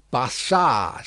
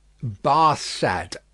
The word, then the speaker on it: Barsad: "Bassard," said Defarge, making it French by pronunciation. But he had been so careful to get it accurately that he then spelt it with perfect correctness. – "Barsad," repeated Madame.